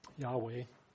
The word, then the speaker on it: Yahweh: Yahweh is probably the best pronunciation.